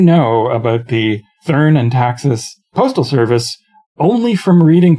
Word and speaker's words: Taxis: the Countess of Taxis, or is it Taxis? – It's since she was probably speaking French, it's Tassis, but okay. Since it's a German title, it's Taxis. – Okay.